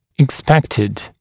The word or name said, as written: expected